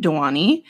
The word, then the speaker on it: Dewani: Dewani. D-E-W-A-N-I. Dewani? – Yeah, that sounds right. – Okay, so Annie Dewani went to South Africa with her husband, Shireen Dewani.